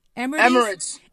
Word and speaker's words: Emirates: Is that how you pronounce it? – Emirates. – Emirates Airline.